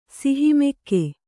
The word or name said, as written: ಸಿಹಿಮೆಕ್ಕೆ